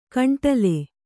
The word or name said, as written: ಕಾಂಡಲೆ